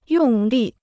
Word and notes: 用力: yòng lì

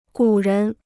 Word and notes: 古人: gǔ rén

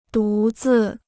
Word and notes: 独自: dú zì

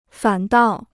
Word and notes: 反倒: fǎn dào

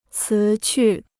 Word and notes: 辞去: cí qù

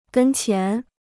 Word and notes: 跟前: gēn qian

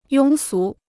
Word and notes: 庸俗: yōng sú

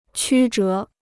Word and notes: 曲折: qū zhé

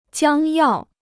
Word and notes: 将要: jiāng yào

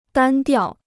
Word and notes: 单调: dān diào

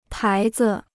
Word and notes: 牌子: pái zi

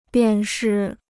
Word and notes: 便是: biàn shì

